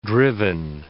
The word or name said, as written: driven